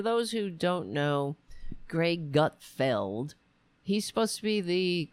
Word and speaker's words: Gutfeld: So, for example, the Greg Gutfeld, is that how you say his name? Yeah, Gutfeld.